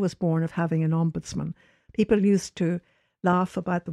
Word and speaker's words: ombudsman: It comes from the Scandinavian ombudsman, which would be the emphasis on the middle part, and it doesn't really mean man.